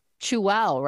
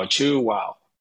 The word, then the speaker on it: Chuao: Welcome, with Chuao, right? That's how you pronounce it. – Perfectly pronounced, yes. You chew and say wow, chew wow. – And it's spelled C-H-U-A-O.